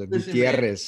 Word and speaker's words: Gutierrez: You have to learn to roll your R's. Gutierrez.